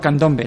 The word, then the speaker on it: Candombe: Asociación de Candombe, si no me equivoco más. Sin más, voy a darle la bienvenida. Buenas buenas tardes. – Buenas tardes. – Lo he dicho bien, ¿no? Candombe, ¿no? – Candombe, sí.